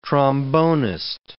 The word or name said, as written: trombonist